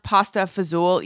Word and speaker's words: pasta e fagioli: But I switched to pasta I believe it is pronounced, I read that it's pronounced pasta fazool, even though it is spelled pasta e fagioli.